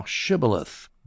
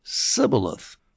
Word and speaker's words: Shibboleth: If he said no, then he would say to him, say now Shibboleth. But he, that's the Ephraimite, said Sibboleth, for he could not pronounce it correctly, couldn't say the H.